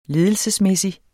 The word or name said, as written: ledelsesmæssig